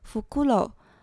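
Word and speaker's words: ふくろう: 但是在日本呢，猫头鹰的发音 “fukuro” 引申出来呢，就有不劳苦，甚至是不老、长寿的意思哦。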